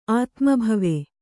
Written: ಆತ್ಮಭವ

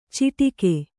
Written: ಚಿಟಿಕೆ

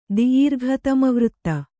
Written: ದಿೀರ್ಘತಮವೃತ್ತ

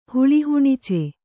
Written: ಹುಳಿಹುಣಿಚೆ

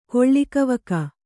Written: ಕೊಳ್ಳಿಕವಕ

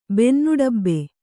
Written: ಬೆನ್ನುಡಬ್ಬೆ

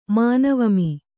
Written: ಮಾನವಮಿ

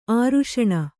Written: ಆರುಷಣ

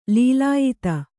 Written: ಲೀಲಾಯಿತ

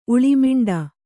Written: ಉಳಿಮಿಂಡ

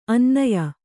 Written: ಅನನ್ಯ

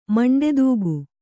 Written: ಮಂಡೆದೂಗು

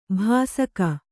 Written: ಭಾಸಕ

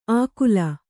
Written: ಅಕುಲ

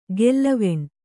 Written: ಗೆಲ್ಲವೆಣ್

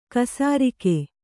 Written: ಖಾಸರೋಗ

